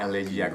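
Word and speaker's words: Élégiaque: Trio Elegiac. – Elegiac, of course, it's French.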